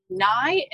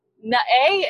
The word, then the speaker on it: Nai: And I'd be like, so N-A-I is pronounced Nai and not like, you know, Na-A. And she's like, no, it's Nai, like why and I?